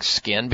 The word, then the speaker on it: 5K1N: And if you write that out, it actually looks like skin, because the 5 looks like an S, then you have K, then the number 1, and then N. Pronounced Skin, their Sunless Tanner.